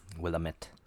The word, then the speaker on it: Willamette: It's refreshing to hear someone not from that area pronounce it right, by the way. – Yeah. It is a weird word. And Willamette.